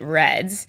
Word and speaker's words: RED-S: saying that it's going to be R-E-D with a small s, so everyone pronounces it REDS.